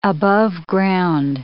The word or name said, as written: aboveground